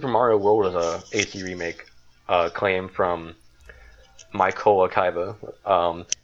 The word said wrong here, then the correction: Mykola Kaba: Mykola Kaba, actually, is how you say his name.